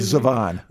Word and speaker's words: Zevon: Zevon.